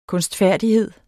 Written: kunstfærdighed

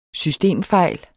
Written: SYSTEMFEJL

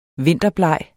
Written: vinterbleg

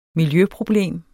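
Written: MILJØPROBLEM

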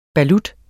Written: balut

